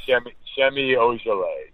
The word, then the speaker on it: Semi Ojeleye: Is it Semi Ojeleye? That's probably way off. – Semi Ojeleye.